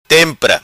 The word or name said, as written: Tempra